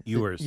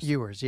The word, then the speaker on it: Ewers: And the dude that's playing, or was playing, how do you pronounce the last name? – Ewers. – Ewers, yeah.